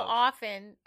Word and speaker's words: often: Often. The T is silent. – Says who? – Helen Norris. – Who's Helen Norris? – Teacher. – Okay. I don't think she's necessarily correct. Say? I think it's just a soft T.